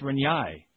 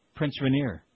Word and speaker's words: Rainier: It says, along the lines of Ruben Sierra and Patrick Sertan, recently I've heard a couple of news anchors pronouncing Prince Rainier as Prince Rinyai.